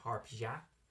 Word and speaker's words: Harpia: Although I think it's technically pronounced, if you go by the correct Greek pronunciation, harpia. Because their scientific name is Harpia harpia. – Harpia harpia. – It's spelled weird, so I'm trying to include the ya.